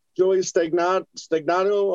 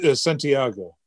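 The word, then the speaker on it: Joey Santiago: We've got Joey Stagnato of the Pixies. If I don't know if I'm saying that right. – Santiago.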